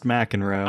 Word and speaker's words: McEnroe: It's pronounced McEnroe.